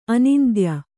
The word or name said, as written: ಆನಂತ್ಯ